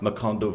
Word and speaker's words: Macondo: Macondo Village Band, yeah.